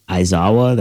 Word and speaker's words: Aizawa: Who gave Aizawa, that's how you say it, right? – Oh, yeah.